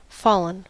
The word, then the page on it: fallen: /ˈfɔː.lən/; [ˈfɔː.lən]